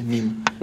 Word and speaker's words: мем: Вот, и он правильно говорится мим.